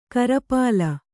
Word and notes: ಕಿರಿಪಿಲ್ಲೆ: kiripille

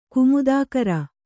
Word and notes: ಕುಮುದಕರ: kumuda kara

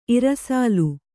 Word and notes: ಇರ್ಸಾಲ್: kannada

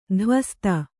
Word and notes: ಧ್ವಸ್ತ: dhvasta

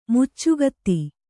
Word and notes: ಮೇಚಕತೆ: mēcakate

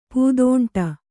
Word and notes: ಪೂದೋಂಟ: Kannada

